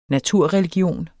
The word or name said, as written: naturreligion